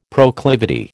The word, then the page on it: proclivity: American English